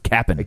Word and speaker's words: captain: I think it's pronounced cap'n. – Cap'n. You're right.